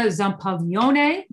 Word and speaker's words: Zampaglione: My name is Francesca Zampaglione. David, I said that especially for you. He likes it when I pronounce it correctly. It's a soft G.